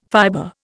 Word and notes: fiber: Indian

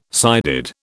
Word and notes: sided: British English

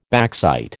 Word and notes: backsight: American English

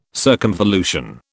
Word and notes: circumvolution: British English

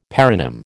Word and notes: paronym: American English

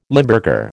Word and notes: Limburger: American English

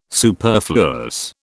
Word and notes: superfluous: British English

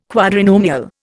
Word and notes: quadrinomial: Indian

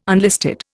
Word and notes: unlisted: Indian

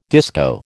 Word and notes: disco: American English

